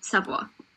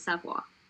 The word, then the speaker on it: Savoie: But, you know, it's Savoie. That's all I'm going to say. Everyone who knows how to say it right says it's Savoie. – Yeah, because that's it's Savoie. – Yeah, because it's literally spelled Savoie.